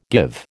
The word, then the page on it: give: American English